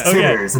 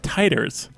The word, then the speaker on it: titers: If you're going for, like, in the blood and immunity, they're pronounced titers. It's not titters, which is why I've laughed every time you say Max Titters, because I'm like, does he mean titers?